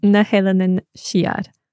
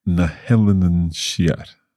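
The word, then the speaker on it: Na h-Eileanan Siar: Do you remember how to say the Western Isles in Gaelic? – It must be Elenin or something like that. – Yeah. Na Helenin Siar. – Na Helenin Siar.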